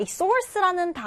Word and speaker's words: source: 자, 이 source라는 단어, 우리 음식에 뿌리는 sauce랑은 철자도 다르고 발음도 다르다. 우리 소스는 이렇게 쓰고 sauce, 그런데 source는 이렇게 R 발음이 또 들어갑니다.